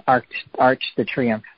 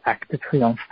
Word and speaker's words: Arc de Triomphe: I saw a picture of Arc de Triomphe, which Americans call it Arch de Triomphe, but it's pronounced Arc de Triomphe.